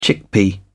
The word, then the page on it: chickpea: British English